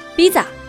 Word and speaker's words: visa: 没有 f 和 v 这两个音的韩国语无法完成 visa 这个发音，所以呢，只能悲催的读成 visa。